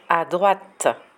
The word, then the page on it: a droite: French